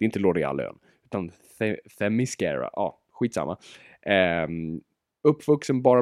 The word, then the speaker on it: Themyscira: det är svårt att uttala, Themyscira. Inte them, många tror att det är, jaha, så det, det är mis, mascara. mascara. – Det har ingenting med mascara att göra.